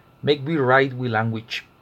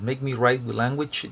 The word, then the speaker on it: Mek wi Rayt wi Langwich: El libro es un alfabeto ilustrado del criollo limonense y, si no me equivoco, se pronuncia Make Me write with Language del Limón Criol Alphabet, publicado por la EUNA, por la Universidad Nacional.